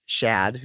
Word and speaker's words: Shad: Yes, thanks, Shad, like Chad, but it's Shad, who's got a sister named Shell, who he has a crush on.